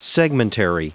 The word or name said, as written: segmentary